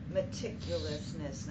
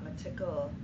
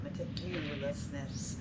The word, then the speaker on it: meticulousness: One quick pronunciation reminder, difference between British English and American English is the U pronunciation. So meticulousness, not meticle, but meticulousness. So, and it's an important word here. And I hear it from many readers, and it's fine to use the British English, but I just want to point out that that's a big difference.